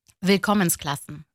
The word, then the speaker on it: Willkommensklassen: Tam telaffuzunu söyleyeyim bana. – Willkommensklassen. – Willkommensklassen. Benimki olmamış, evet.